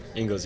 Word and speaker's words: Engels: Engels, right? – Engels, yeah. – Engels. I always say it wrong. – It's Angel in German, brother.